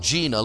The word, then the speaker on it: Jena: Uncle Don was raised in a little town called Gena, Louisiana. It's spelled J-E-N-A.